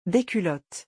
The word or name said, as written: des culottes